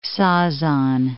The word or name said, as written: Sazan